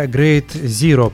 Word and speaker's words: Grade Zero: А проект называется Great Zero, правильно я произношу? Да, все так.